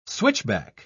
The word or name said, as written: switchback